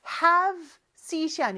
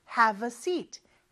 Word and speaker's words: have a seat: 그리고 발음할 때는 어가 have에 묻혀서 잘 안 들린다고 했죠. have seat이 아니고 have a seat. have a seat. 살짝 어가 들어갑니다.